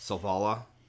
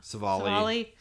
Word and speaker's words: Savali: Savala? I'm saying that wrong. I know. – I think it's Savala. – Savali.